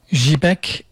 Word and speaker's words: Zhibek: Zhibek in Kazakh means silk. Zhibek. In Turkish, it becomes ipek. We dropped the, in Turkey, they dropped the Z sound at the beginning.